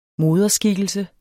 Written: moderskikkelse

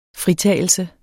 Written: fritagelse

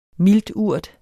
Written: milturt

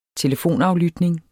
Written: telefonaflytning

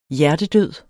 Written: hjertedød

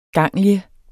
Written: GANGLION

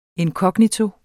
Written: inkognito

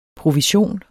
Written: provision

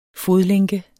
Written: fodlænke

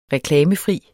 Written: reklamefri

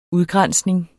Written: udgrænsning

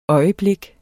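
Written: øjeblik

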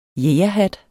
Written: jægerhat

